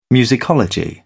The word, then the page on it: musicology: British English